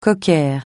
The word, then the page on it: cocker: francais